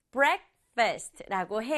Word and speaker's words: breakfast: breakfast라고 해요. 엄청 긴데요. 친구들 이 단어는 두 박자로 발음해요. breakfast. breakfast. 이렇게 앞에 강조줘서 breakfast.